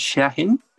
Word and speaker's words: Chajin: It's pronounced Chajin. – Like, Chajin? – Yeah. It's Lebanese.